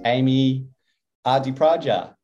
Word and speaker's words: Amee Ardipradja: Her name is Amee Ardipradja. Did I pronounce that correctly, Amee? Yeah.